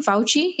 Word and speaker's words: Fauci: Fauci? Yeah, Dr. Fauci was saying that to eliminate handshakes forever,